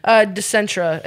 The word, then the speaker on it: Dicentra: Dicentra.